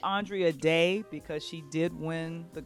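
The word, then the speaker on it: Andra Day: I'm saying Andrea it because Day. All of us were pronouncing it differently for like years. She never corrected anybody. Now it's Andrea Day.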